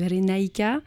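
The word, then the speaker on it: Re Naiqa: Et pour suivre tout ça, vous pouvez aller sur la page Facebook de Ray Naïka, qui s'écrit R-E plus loin, N-A-I-Q-A, voilà. Et on a aussi une page officielle sur Diffie Music, Diffie comme Do It For Your Music, slash Renaika.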